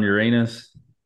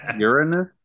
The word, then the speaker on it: Uranus: You ever seen the rings around Uranus? – Oh. – Pretty cute. – It's actually pronounced Uranus.